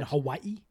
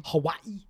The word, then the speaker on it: Hawaii: Hawaii? – Hawaii. There's that apostrophe between the eyes. – Yeah. So that's why you got to say it that way. If you want to say it like the locals.